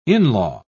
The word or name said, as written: in-law